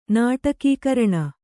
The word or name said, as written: ನಾಟಕೀಕರಣ